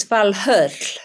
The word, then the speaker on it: Valhöll: Well, in Old Norse, it's Valhöll.